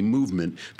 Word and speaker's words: MVMT: This one actually is spelled MVMT, movement, because for some reason these new companies, they can't use vowels.